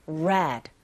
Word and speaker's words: red: red라는 r 발음과 lake이라는 l 사운드가 동시에 나왔기 때문에 이게 막 혼동되는 경우가 있습니다. 천천히 r 사운드 첫음 내실 때는 우 하시면서 red, red.